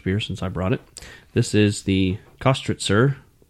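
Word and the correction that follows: Köstritzer: You have the umlauts of the O. – Kostritzer. – There you go.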